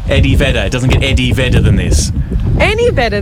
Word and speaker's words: Eddie Vedder: Eddie Vedder with a V.